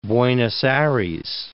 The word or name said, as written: Buenos Aires